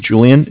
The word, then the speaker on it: Julian: My name is Julian. It's J-U-L-I-A-N. – Okay. – Calling from Bank of Cardiff. Cardiff is spelled C-A-R, D as in David, I-F-F as in Frank Frank.